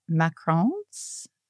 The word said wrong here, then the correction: macarons: Oh, macarons. Yep.